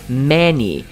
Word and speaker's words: many: E às vezes as pessoas confundem a pronúncia dela com dinheiro, gente. Dinheiro em inglês é money. Money. E esse aqui é many.